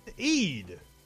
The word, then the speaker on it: Eid: Al-Hada? I couldn't have said that right. – Eid. – That phonetic pronunciation, Wikipedia, was weird. Eid.